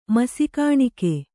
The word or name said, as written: ಮಸಿಕಾಣಿಕೆ